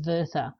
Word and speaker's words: Werther: So he starts with, and this is something that was mentioned in the introduction about, now I think it's pronounced Goethe's Werther.